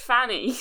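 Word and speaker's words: Pfannee: It's silent P. Why? – Perfanny. Yeah, it does say pronounced just Fanny. I don't think the P's. – Silent P. – Yeah, silent P.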